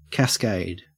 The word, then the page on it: cascade: Australian English